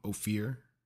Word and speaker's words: Ophir: Also, I need to come clean and tell you I've mispronounced the name of the city. It's Ophir is the name of the city.